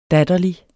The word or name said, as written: datterlig